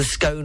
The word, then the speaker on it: scone: So scone is scone to rhyme with gone rather than scone to rhyme with bone.